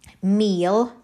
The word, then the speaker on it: mil: a thousand, meal and is spelt as in is pronounced as in meal the English but spelt M I L.